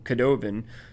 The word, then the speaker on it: Cadogan: Actually, who I call Sir Cadogan, a lot of people pronounce him Cadovan.